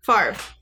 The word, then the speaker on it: Favre: Favre.